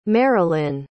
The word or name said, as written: merilyn